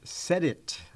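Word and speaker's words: CEDIT: He's the executive director of EKU's Center for Economic Development, Entrepreneurship, and Technology, pronounced SEDIT.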